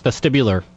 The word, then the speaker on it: vestibular: vasticular syndrome. – Okay. Very close. It's vestibular. – Vestibular, okay. Yes.